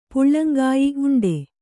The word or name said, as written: ಉಂಡಿ